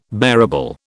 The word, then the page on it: bearable: British English